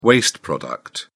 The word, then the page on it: waste product: British English